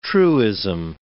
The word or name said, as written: truism